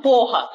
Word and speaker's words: poha: Ou em vez de ser esse arroz que é o pulau, pode ser um arroz chamado porra. Só que se escreve P-O-H-A.